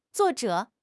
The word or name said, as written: zuo4 zhe3